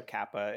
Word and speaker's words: SOCAPA: I know we've talked about you, I don't know if I'm pronouncing this right, SOCAPA or SOCAPA? – It's called, the School of Cinema and Performing Arts. Okay. SOCAPA.